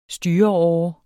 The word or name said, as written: styreåre